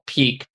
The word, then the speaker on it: Pique: I took a book out of Tim Ferriss's world, and I got a lot of my family this high-end tea called Peak, P-I-Q-U-E.